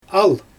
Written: al

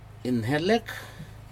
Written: inhelek

